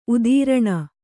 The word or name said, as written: ಉತ್ತರಣೆ